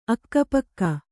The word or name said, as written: ಅಕ್ಕಪಕ್ಕ